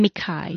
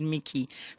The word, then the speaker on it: Mikai: And Mickey, is that how you pronounce his name? – Mikai. – Mikai. So it's not your typical Mickey that you normally see. The American way they would pronounce it, Mickey.